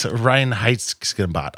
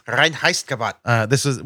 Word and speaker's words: Reinheitsgebot: It's Reinheitsgebot. I wish I could say it properly. – I was close. – You were so close. – Reinheitsgebot.